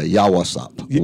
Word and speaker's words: Yawasap: It's Yawasap. – Yeah, Yawasap. Y-A-W-A-S-A-P. – Yawasap. – Yes.